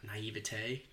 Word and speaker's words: naivete: Now I think there's probably a lot of naive, na- naivete. Did I say it right? – Nailed I it. I, Nailed it.